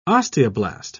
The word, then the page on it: osteoblast: British English